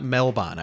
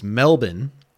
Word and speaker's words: Melbourne: It's not Melbourne. I was corrected on how to pronounce it by some social media folks. It's Melbourne.